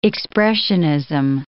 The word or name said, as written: expressionism